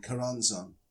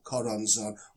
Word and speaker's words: Choronzon: And the idea is to cross the abyss and to get beyond this demon, Karanzon or Koronzon or however you want to pronounce it. It begins with CH, so you could say Choronzon.